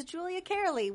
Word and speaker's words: Julia Cearley: which is Julia Carley, which is spelled JULIACEARLEY.